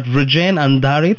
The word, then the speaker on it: Vrujen Andhare: I've got Vrujen, Andhare.